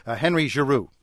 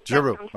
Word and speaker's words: Giroux: As somebody who's butchered more than a few names myself, it's I'm told pronounced Henry Giroux. – Giroux.